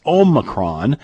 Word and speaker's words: Omicron: Man, I keep saying it wrong. It's Omicron.